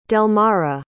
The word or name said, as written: delmara